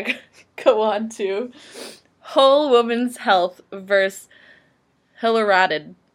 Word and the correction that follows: Hellerstedt: I'm so sorry to whoever last name I just butchered. Oh, Hellerstedt. – Wow. – I can't even read my own typing. Hellerstedt. I think that sounds better.